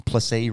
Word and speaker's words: Plessis: Plessis rhymes with Pepsi.